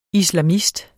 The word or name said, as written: islamist